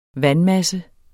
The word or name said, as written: vandmasse